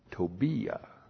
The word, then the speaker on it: Tobiah: Actually, you pronounce it Tobiah. That's T long O B I Y A H. That's how it looks like in the Hebrew. In the English, you have Tobijah.